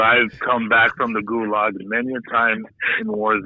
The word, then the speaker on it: Gulag: Okay, it's pronounced Gulag. Right? – Are you serious? – It's the damn Gulag.